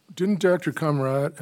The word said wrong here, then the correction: Comaritis: how do you pronounce his last name? – I believe it's Comaritis, Your Honor.